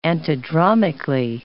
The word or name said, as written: antidromically